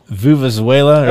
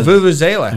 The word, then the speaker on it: vuvuzela: Uh oh, vuvuzela. – Oh, vuvuzela.